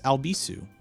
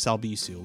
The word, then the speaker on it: Albisu: by just searching for Alex Albisu, at Alex Albisu. Last name is spelled A-L-B as in boy, I-S as in Sam, U.